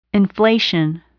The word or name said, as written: inflation